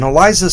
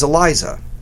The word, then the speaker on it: ELISA: And ELISA is spelled E-L-I-S-A.